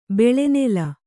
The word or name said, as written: ಬೆಳೆನೆಲ